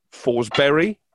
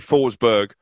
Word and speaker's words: Forsberg: And I think they have a goal threat in Forsberg, is the correct pronunciation. It will be Forsberg on TalkSport 2 next Tuesday.